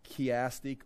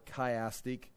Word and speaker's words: chiastic: I actually looked the word up to see how to pronounce it, and it's pronounced chiastic or chiastic.